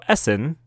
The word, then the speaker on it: Essen: So Essen, pronounced Essen, is a city in Germany.